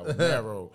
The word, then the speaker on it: Morrow: Man, you sound like Wallow right now. Morrow. – Morrow. – That's that E-coat. That's you talking like bone marrow.